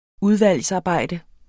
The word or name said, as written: udvalgsarbejde